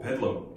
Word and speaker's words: Bedlam: So 거기 Bethlehem 이렇게 좀 발음 빨리 하면은 Bethlehem 아니고 Bedlam.